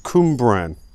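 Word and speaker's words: Cwmbran: It says here, it's pronounced Cumbran. It's quite an easy one, really. It might be quite an easy one for you, but I don't know how to pronounce a W as not a W.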